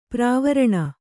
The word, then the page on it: ಪ್ರವಾರಣ: pravāraṇa